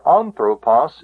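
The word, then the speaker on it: anthropos (ἄνθρωπος): But here, anthropos, the accent is on the antepenult, the third syllable back.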